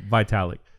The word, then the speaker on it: Vitalik: Vitalik. – Vitalik.